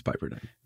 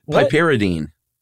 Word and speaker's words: piperidine: And those in the know, piperidine. – I think it's piperidine. – Those in the know who don't smoke PCP, but study it. Oh no, you got it wrong. Piperidine.